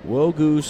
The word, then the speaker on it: Nwogu: Wogu spelled N-W-O-G-U. Silent N.